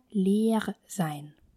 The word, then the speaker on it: leer sein: Первый вариант – это выражение leer sein. Leer sein. Leer sein. Переводится как «быть пустым» поскольку в слове «удвоенное гласное» она будет произноситься долго.